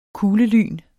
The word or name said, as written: kuglelyn